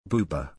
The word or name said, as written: booba